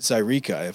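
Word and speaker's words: Zaireeka: You're coming off of Zyreka, if I'm saying that right. – You are indeed.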